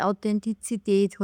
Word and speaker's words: Authentizität: Er verliert seinen Charme, seine Ecken, seine Eigenheiten und vor allem die Authentizität. Heute ist richtig ausgesprochen. – Authentizität. Das ist yes, einmal richtig.